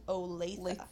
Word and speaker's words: Olathe: Not Olathe or Olathe. Anybody who's not from Kansas, it's pronounced Olathe.